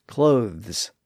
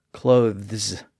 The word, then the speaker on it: clothes: By the way, it's really difficult to pronounce that voiced TH sound before an S. Clothes. It's very awkward. And that voiced TH sound tended to disappear in words like that. That's why many people today wear clothes, not clothes. They don't pronounce the TH sound in the word.